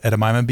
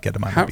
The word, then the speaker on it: edamame: store and you can get this big packet of individual packets of Edamame beans.